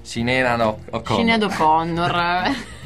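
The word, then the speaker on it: Sinéad O'Connor: Sinead O'Connor.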